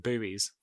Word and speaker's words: buoy: Is it buoy or buoy or? – I think it's buoy. – English is buoy and Americans call them buoys.